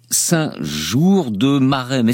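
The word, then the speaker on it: Saint-Geours-de-Maremne: J'ai parlé d'un village Saint-Jour-de-Marennes. Est-ce que je l'ai bien prononcé? – Parce absolument, Saint-Jour-de-Marennes dans les Landes.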